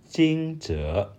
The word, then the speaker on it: Jingzhe: But Jingzhe, the how, is that? Did I say that right? – Jingzhe. – Jingzhe. – Yes.